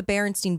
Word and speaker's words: Berenstain: But that Berenstain Bear one is like a huge one. So if you know the Berenstain Bears, and I'm going to say Berenstain because goddamn it, it's Berenstain.